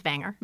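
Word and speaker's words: Vanger: Wagner family. – Right. – I don't know if I'm saying that one right either. – I think it's Wanger. – Wanger. My pronunciation is all wrong.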